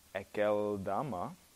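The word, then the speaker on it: Akeldama: Verse 19, "And it became known to all the inhabitants of Jerusalem, so that the field was called in their own language Ekeldama." That's the right pronunciation, in case you were wondering.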